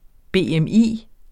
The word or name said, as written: BMI